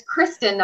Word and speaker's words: Kristen: and, and, it's Kristen, not Kirsten.